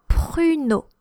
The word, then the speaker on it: pruneau: But when a plum is dried in French, so a prune, we call un pruneau. And close enough to prune. It's spelled P-R-U-N-E-A-U, un pruneau.